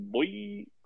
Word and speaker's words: Boye: I believe it's pronounced Boye. – Boye.